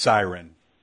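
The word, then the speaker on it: SYRN: It's S-Y-R-N, but it's pronounced siren.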